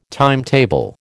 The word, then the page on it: timetable: American English